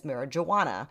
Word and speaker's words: marihuana: And I put the emphasis on the H because it was spelled M-A-R-I-H-U-A-N-A, because this was a time when people weren't familiar with the fact that you don't pronounce a J in Spanish as marijuana.